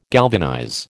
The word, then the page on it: galvanize: American English